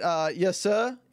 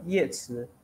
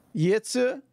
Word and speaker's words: Yetsu: Is it Yesa? Is that how you say her name, like her actual name? – No, Yetsu. – Yetsu? Oh yeah, because Caesar Tsa.